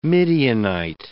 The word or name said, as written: Midianite